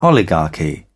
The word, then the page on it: oligarchy: British English